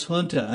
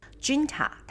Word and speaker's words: junta: Three, Myanmar's junta announced it would free over 9,000 prisoners to mark the country's Independence Day, though there was no indication that the figure would include any of the country's 17. Zero, zero, zero political prisoners. The country's oppressive government has suffered a series of astonishing military defeats since an offensive launched by a rebel coalition of ethnic armies last October. – Junta，它会念成了那个 J，它会发着 H 的音，因为呢，就是在以西班牙文的发音来讲的话，J 它会发 H 的音，所以呢，呃，西班牙人的人啊，或者是我阿根廷室友，他们的哈哈哈哈会打的是 J A J A。